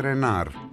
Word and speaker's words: Reinhardt: Που η σωστή προφορά, λέει, του ονόματος του στα γαλλικά είναι Renard, αλλά όλοι Reinhardt τον λένε.